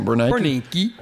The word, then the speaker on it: Bernanke: Bernanke.